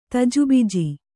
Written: ತಜುಬಿಜಿ

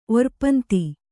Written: ಒರ್ಪಂತಿ

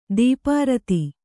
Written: ಧೂಪಾರತಿ

